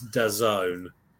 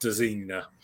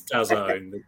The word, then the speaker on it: DAZN: It's DAZN, not DAZINA. It's DAZN, the AZN.